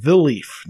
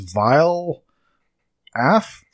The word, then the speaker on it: Vileaf: Tuesday, November 16th, the Smurfs Mission Vileaf. Now, it looks like it's Vile Af, but it's pronounced Vileaf if you listen to the trailer.